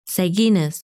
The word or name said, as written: Seginus